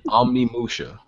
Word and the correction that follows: Onimusha: Onimusha. – Yeah, oh, I always say it wrong.